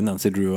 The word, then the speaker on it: Nancy Drew: For jeg leste om Nanki Drev, mm. og sa til min mor at Nanki Drev er så morsom. Og så sa hun det uttales Nancy Drew, altså.